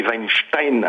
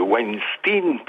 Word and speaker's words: Weinstein: Fabienne Saint-Esse, pourquoi prononcer Weinstein à l'allemande alors qu'il s'agit d'un nom anglo-saxon et qu'on prononce au mieux Weinstein pour les vrais anglo-saxons ou à la française Weinstein? Mais je vous en conjure, pas Weinstein, ça me fait encore une fois dresser les quelques cheveux qui me restent sur la tête.